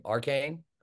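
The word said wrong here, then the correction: Achane: Is that it? – A-chain. – I knew I'd butcher it. A-chain.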